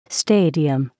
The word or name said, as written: stadium